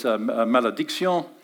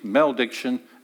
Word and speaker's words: malédiction: And I discovered that the French word for cursing is malédiction, And the French word for blessing is benediction, and if I give it an English pronunciation, it'd be malediction and benediction.